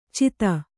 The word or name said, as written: ಛೇದಿ